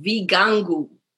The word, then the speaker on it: Vigangu: So in the plural, they are called Vigangu. And in the singular, a Kigangu. So one Kigangu, many Vigangu.